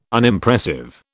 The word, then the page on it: Unimpressive: British English